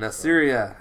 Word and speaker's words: Assyria: And Assyria, so. I think that's how you pronounce it. It's like Syria, but with an A at the beginning of it.